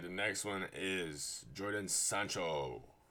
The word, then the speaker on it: Jadon: I'm pretty sure it's Jadon, though.